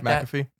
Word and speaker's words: McAfee: But yeah, he went on the Pat, I don't know how to say his last name, McAfee, something like McAfee? – That. McAfee. Pat McAfee show and did like a whole interview about this.